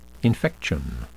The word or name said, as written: infection